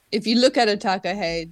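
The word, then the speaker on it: takahē: So, anyone out there listening on the gargle, it's go to find the takahe. There's an accent on the E,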